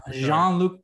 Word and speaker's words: Jean-Luc: Jean-Luc Picard.